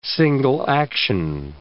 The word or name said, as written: single-action